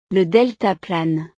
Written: le deltaplane

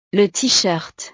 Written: le teeshirt

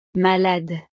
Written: malade

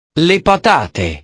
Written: le patate

